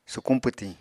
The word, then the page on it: sukuñ pwtiñ: Apatani